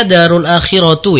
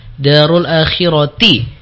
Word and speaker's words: دار الآخرة: Jadi terbacanya darul akhiratu ya, harusnya darul akhirati. Ya, jadi di situ kasrah ya, bukan dhammah.